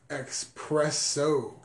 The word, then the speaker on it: espresso: Is it espresso or is it espresso? – No, it's espresso. – Okay. – Say it like the gabachos, please. – Got it. Espresso. – There you go. Exactly. – I wasn't sure if it was S or X.